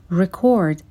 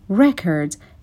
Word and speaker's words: record: این تفاوت تلفظی رو دقت می کنیم که وقتی record یک فعل هستش به این صورت تلفظ میشه. استرس روی بخش دوم کلمه هست. Record his progress. ولی وقتی که اسم هست میشه record. استرس روی بخش اول کلمه میاد.